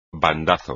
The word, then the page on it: bandazo: espagnol